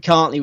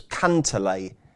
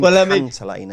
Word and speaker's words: Cantlay: Or if you watch the Masters coverage, did you hear how Paul McGinley pronounced it? It was Cantlay. He put an extra syllable in there. Yeah, Cantlay. – Cantlay. – So I call him Cantlay now.